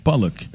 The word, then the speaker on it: potluck: Potluck. – A gente fez muito isso nas festas juninas. – Mas na verdade se escreve potluck, né? – É, escreve pot. – O T, eu elimino o T aqui.